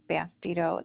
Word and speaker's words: Bastedo: My name is Chris, K-R-I-S. Last name is Bastido. It's spelled B-A-S-T-E-D-O.